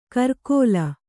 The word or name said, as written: ಕರ್ಕೋಲ